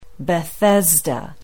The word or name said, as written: bethesda